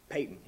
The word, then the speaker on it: Paton: Go read about a missionary, John Payton, P-A-T-O-N, but it's pronounced Payton.